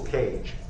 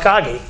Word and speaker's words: Kage: Yeah, somebody told me that instead of Uncle Kage, the guy says Uncle Cage. – Yeah, there's some people have said that. Oh, Uncle Cage. I said, no, it's pronounced Kage.